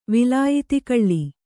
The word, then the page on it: ವಿಲಾಯಿತಿಕಳ್ಳಿ: Kannada